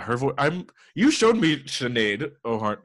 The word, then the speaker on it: Sinead: And a song called Settle with Sinead Harnett. – Sinead. – Is that how you say it? – Uh-huh. – Sinead. – It's a very Irish name, I think. – The H is not there at all.